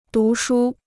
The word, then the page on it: 读书: dú shū